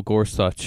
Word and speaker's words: Gorsuch: Trump nominates Neil Gorsuch. Is that how you say it? – Yes. – Gorsuch? Okay. – Exactly.